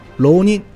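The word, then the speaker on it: 牢人: 这个劳人读音和这个浪人是一样的，劳宁，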